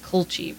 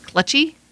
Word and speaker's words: culchie: It's spelled C-U-L-C-H-I-E, but it's set, instead of, I thought it would be pronounced culchy, but it's pronounced clutchy, and it literally means hick.